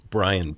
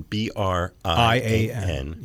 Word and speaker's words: Brian: Brian, B R I A N, yeah.